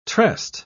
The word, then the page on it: tressed: British English